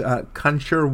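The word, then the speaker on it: Conchúr: It's Conchur White. That was my best shot, C-O-N-C-H-U-R, with an accent on the U, and a song called I Did It Good Today.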